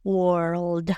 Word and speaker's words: world: Empezamos con war. Igual que la palabra guerra, war, pero en mundo le vamos a meter la L y la D. World. World world. L-I-D, world.